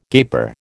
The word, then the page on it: gaper: American English